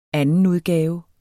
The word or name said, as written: andenudgave